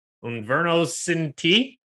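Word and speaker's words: Un Verano Sin Ti: ABBA, Voyage, Adele, 30, Bad Bunny, Inverno Cinti. I'm probably saying that wrong. – Perfect. Nailed it.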